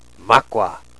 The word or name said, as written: makwa